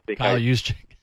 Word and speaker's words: Juszczyk: I kind of related him to the fullback of the San Francisco 49ers, Juszczyk. I can't say his name, so my apologies for butchering it. – A Harvard great, Kyle Juszczyk.